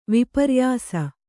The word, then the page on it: ವಿಪರ್ಯಾಸ: viparyāsa